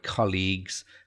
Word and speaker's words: colleagues: So the stress on the word colleagues, and that it's spelt C-O-L-L-E-A-G-U-E-S, but the pronunciation is really important.